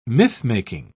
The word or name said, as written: mythmaking